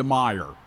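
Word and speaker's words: Meijer: You know, you get to run to Meyer or whatever. I'm sorry, Myers is because you have to pronounce the S. It isn't actually there.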